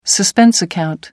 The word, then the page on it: suspense account: American English